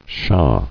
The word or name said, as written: Shah